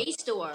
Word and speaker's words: JSTOR: Oh, Jstor. – Jstor. I always say it wrong. – You were saying Jester, and I was like, what? No, you have Jstor.